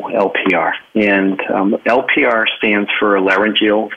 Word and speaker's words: laryngeal: I'm not sure I'm pronouncing it correctly. Laryngeal reflux. So you'll have to correct me and tell us about that. – No, perfect. It's absolutely perfect.